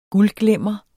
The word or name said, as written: guldglimmer